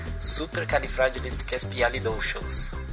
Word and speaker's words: Supercalifragilisticexpialidocious: E o jeito certo de falar a palavra é... Oh, presta atenção que eu vou falar uma vez só. Supercalifragilisticexpialidocious.